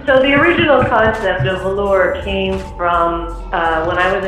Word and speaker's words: Velours: The concept is actually, I'm using the French spelling and pronunciation, so that S is actually silent. So it's just Velours, like the way you say it in English.